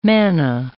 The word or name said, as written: manna